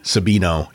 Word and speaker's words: Sabino: I don't know if I'm saying that right. Sabino? – Sabino, yeah. – Sabino. – Sabino Canyon. – Spell it. – S-A-B-I-N-O.